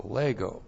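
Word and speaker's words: lego (λέγω): L-E-G long O, lego.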